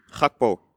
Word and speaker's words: Gakpo: Gakpo.